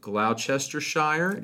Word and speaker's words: Gloucestershire: and the University of Gloucestershire, if I pronounce that That's close, yeah.